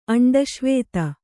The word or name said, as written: ಅಂಡಶ್ವೇತ